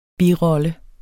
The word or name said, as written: birolle